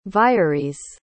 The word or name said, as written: vires